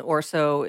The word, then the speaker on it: Erso: I don't know if Jyn Erso is having Erso. – Thank you. Erso. What was I saying? Erso. – Erso. – Erso. – Erso. – Like OR instead of ER.